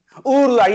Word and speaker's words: Urlar: Urlar, yeah, that G with a thing on top of it is a silent G in Turkish, actually. So it's like a long U.